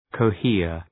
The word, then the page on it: cohere: kəʋ’hıər